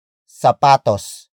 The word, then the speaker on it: sapatos: Ang is spelled as A-N-G. It is the focus marker that introduces the main noun of the sentence. And that is sapatos. – Sapatos. Sapatos. Sapatos is spelled as S-A-P-A-T-O-S. It means shoes, either as a single piece of shoe or a pair of shoes.